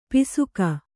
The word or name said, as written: ಪಾಸಿಕೆ